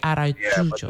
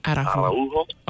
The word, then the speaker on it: Araújo: No, that was very good. Arujo. You missed the extra A there. A-R-A-U-J-O. – Okay, so it's Arujo. – Arujo, yes. – Oh, is it Spanish? – It's Portuguese. – Portuguese. Okay, Araújo. – Yeah, the name exists in Spanish as well, but it's pronounced Araújo. – Araújo. Okay. All right. – Because the J is a little different, but yeah.